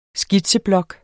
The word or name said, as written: skitseblok